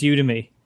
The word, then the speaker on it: Udemy: It's a play on the word academy. So if you use that pronunciation, then it's Udemy. I can't say academy the way that you'd say Udemy. maybe academy is pronounced different around the world too. But to me, it's the Academy of You is their tagline. So I guess it's pronounced the same as academy, which is Udemy to me.